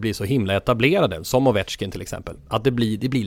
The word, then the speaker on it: Ovechkin: Sluta säga Avechkin. Han heter ju Ovechkin, säger folk.